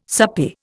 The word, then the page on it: soupy: Indian